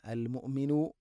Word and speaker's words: المؤمن: المؤمن المهيمن, المؤمن مؤمن അല്ല المؤمن, ഹംസയുടെ ഉച്ചാരണം കൃത്യമാക്കണം.